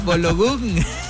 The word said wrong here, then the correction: Balogun: Balogun es.